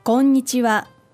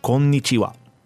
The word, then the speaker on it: こんにちは: こんにちは. – Perfect. – Okay. Again, pronunciation will be key here. You'll notice in the Romaji, or the Romanized characters, there's two N's. Some people just overlook this.